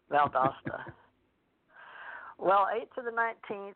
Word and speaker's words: Valdosta: Or is it Valdosta? How do you say it? Valdosta? Valdosta, Georgia. – Valdosta? – Seriously? No way. It's Valdosta.